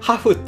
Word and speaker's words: HAFH: でね、えー、一つ最後にね、おすすめのサービス、昔紹介したんですけど、ハーフ。ハフっていうね、 HAFH って読みにくいんですけど、ハフ、